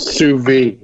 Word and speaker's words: sous vide: They do sous vide, I think that's how you say it, where they cook. – Sous vide, yeah.